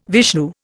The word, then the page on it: Vishnu: Indian